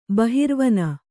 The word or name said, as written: ಬಹಿರ್ವನ